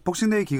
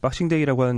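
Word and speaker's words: Boxing Day: 일단 손흥민 선수 얘기를 다시 해보자면 복싱데이 기간에도 손흥민 선수는 계속 나오겠죠? 네, 어, 우리는 박싱데이라고 하는데 이건 기자는 꿋꿋하게 복싱데이라고 네. 영국 발음으로 그쪽 발음이죠?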